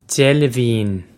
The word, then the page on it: deilbhín: djel-liv-een